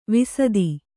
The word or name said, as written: ವಿಸದಿ